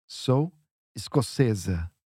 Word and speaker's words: escocesa: The thing there is that we need a Z sound at the end. Sou escocesa.